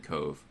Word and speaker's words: CoV: but I just hate when they say the CoV rather than cove.